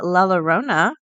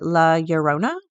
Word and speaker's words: La Llorona: We are talking about our urban legend of the day, and it is the La Llorona. See, I wonder if it's Lala. So everyone pronounces it La Llorona, but I'm pretty confident the double L is a Y sound.